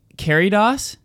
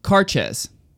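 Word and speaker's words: Cairdeas: We've been saying Caridos. That's totally wrong, by the way. – Oh, I said that because that's what you said. – Oh, well, it's wrong. – Okay. – Carches. Carches is how it's pronounced. – Sure, of course it is. – Naturally. – Because that's why the D is there. – Right. – And that's why it's spelled like Caridas. – Right. – Or Caridias. – Right. – Carches. – Carches. That's how you pronounce it.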